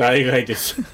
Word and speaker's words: 代替: 代替です。 – 代替と書いて代替と読むんだって代替です。